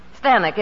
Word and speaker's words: Stanwyck: Well, that's how they said Stanwyck in England. – Oh. – Stanek, it rhymes with Zanuck.